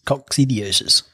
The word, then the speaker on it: coccidiosis: Yes, coccidiosis. That's how you say it.